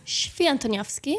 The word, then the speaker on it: Swietoniowski: Swietoniowski.